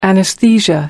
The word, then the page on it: anesthesia: American English